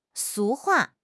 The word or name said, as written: 俗话